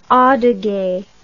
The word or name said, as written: adyge